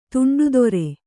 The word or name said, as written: ದಂಡಧಾರ